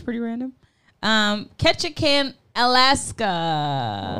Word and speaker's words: Ketchikan: Ketchikan. – Ketchikan.